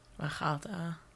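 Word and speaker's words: Achalta (אכלת): With the accent on the middle the syllable there. Achalta, right? That sounds much more natural. Achalta sounds like someone who doesn't know Hebrew very well and thinks that everything is supposed to, every accent is supposed to be on the last syllable, right? – Yeah.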